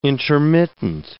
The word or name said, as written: intermittent